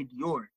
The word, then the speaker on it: Dior: He used to call me Buongiorno, DuJour. He could never say Dior.